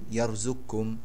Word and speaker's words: يرزقكم: فيقرأها السوسي يرزقكم واثقكم يقرأها السوسي واثقكم وخلقكم يقرأ خلقكم يقرأها السوسي خلقكم والنطق هنا بكاف خالصة لا أثر للقاف فيها